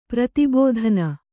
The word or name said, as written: ಪ್ರತಿಬೋಧನ